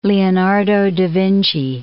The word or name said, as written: Leonardo da Vinci